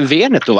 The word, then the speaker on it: Veneto: Vi, va, prosecco, då ska vi till Veneto va? Eller vad ska vi då? – Ja precis, gud vad bra ditt uttal har blivit nu.